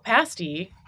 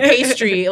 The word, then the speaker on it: pasty: Oh, pasty. And we love hearing people pronounce it. – Yeah. – They put the R in there. – Or pastry. A lot of people just think it's pastry.